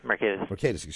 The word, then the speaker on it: Mercatus: Mercatus? – Mercatus.